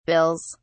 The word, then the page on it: bills: British English